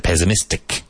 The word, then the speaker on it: pessimistic: Pessimistic. Pessimistic. Even though it's spelled with double S, it's pronounced like a Z.